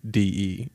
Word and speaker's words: Saudade: And I'm going to say it wrong, so correct me. It looks like Saudade. – Oh, that sounds better than the way I would pronounce it. – What, Saudade? Saudade? – I would say Saudade, but that might be the Deep South version. – Sounds like Deep South version. But what? Okay, it's Saudade.